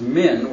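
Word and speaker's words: min (מן): There's a preposition in Hebrew pronounced min,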